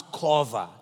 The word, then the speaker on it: qavah: The word wait in Hebrew, if you want to write it down, is the word Q-A-V-A-H. Pronounce kova. Q-A-V-A-H. Kova.